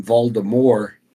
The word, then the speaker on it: Voldemort: When he's talking and I believe he narrated them the first couple books before the movies came out, because they called him Voldemort and not Voldemort, because the movies they called him Voldemort with, they pronounced the T.